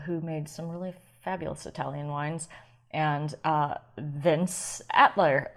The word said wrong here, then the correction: Anter: I just mispronounced his name, Anter, Vince Anter, who he hosts a show that you can find.